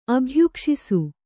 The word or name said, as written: ಅಭ್ಯುಕ್ಷಿಸು